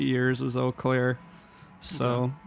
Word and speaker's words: Eau Claire: E-A-U, pronounced Eau. – Eau Claire, French.